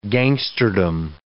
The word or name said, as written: gangsterdom